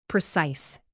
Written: precise

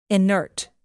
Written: inert

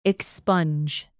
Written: expunge

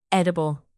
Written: edible

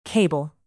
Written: cable

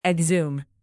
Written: exhume

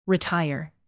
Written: retire

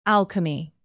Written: alchemy

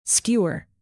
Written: skewer

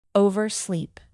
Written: oversleep

